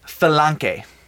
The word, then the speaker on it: phalange: It is pronounced phalange. – Phalange. – Phalange. It's like a fel and gay, only the gay part sort of has like a K to it, so it's phalange.